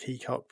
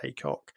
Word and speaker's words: Heacock: So Clint Haycock, actually spelled H-E-A, so it looks like Heacock, but he pronounces it Haycock.